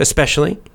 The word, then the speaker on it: especially: So both pronunciations are fine. You can say especially if you want to, especially, especially, especially, or you can put the little k, the little kind of K sound in there, especially, especially, especially. It's also fine.